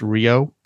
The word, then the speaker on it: REO: It's pronounced Rio, but the band just wanted to call it REO to be cooler.